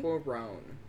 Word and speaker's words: Cuarón: No, it's because you're putting the emphasis on the wrong syllable. So just, I would say, like, Cuarón. – Cuarón.